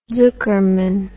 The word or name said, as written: zuckerman